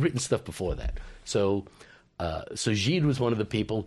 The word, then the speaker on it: Gide: André Gide. No, Gide, that's right.